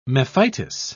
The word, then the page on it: mephitis: British English